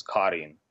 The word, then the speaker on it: Kareen: I had to do a little phonetical change because Kareen on any in any historical translated document is spelled K-A-R-I-N, right? Kareen. But yeah. I think for phonetic reasons, I just added the double E.